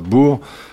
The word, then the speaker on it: Boer: Arrêtons-nous un instant sur ces deux guerres englobeurs, euh, anglo euh, on dit, donc on écrit ça Boer, mais on prononce ça bourg.